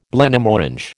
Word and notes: Blenheim Orange: American English

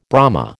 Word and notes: Brahma: American English

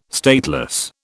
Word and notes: stateless: British English

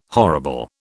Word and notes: horrible: British English

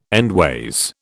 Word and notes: endways: British English

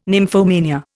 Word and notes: nymphomania: Indian

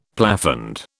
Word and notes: plafond: British English

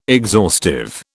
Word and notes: exhaustive: British English

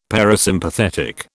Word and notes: parasympathetic: British English

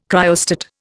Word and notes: cryostat: Indian

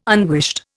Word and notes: unwished: Indian

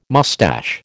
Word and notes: moustache: American English

